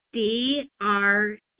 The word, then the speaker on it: Drago: DRAGO.